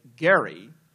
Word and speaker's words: Gerry: I want to say something about districting and gerrymandering, and in particular, partisan gerrymandering, which, and gerrymandering apparently is a mispronunciation. because, of course, it is named after Elbridge, as he would say, Gerry,